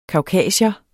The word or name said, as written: kaukasier